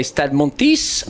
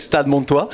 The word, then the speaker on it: Stade Montois: Yeah, so it's funny because you say Stade Montis, and this is Stade Montois.